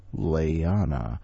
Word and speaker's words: Leana: Okay, folks, we're going to learn how to say the word Leana. You say lay, e, ah, na, lay, e, ah, na. Leana.